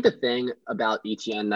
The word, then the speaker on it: Etienne: Yeah, so my running back one is Travis Etienne, whom I learned his name is pronounced Etienne. I thought it was Etienne at first. You know, I'm a French speaker, but it is, in fact, Etienne.